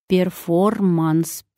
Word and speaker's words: перформанс: Звук «р» в корне слова чаще всего не произносится, но в написании букву нужно сохранять. «Перформанс» пишем, «перформанс» произносим.